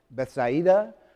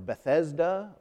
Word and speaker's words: Bethesda: There's several different ways of pronouncing it. Bethsaida or Bethesda or Bethzeda.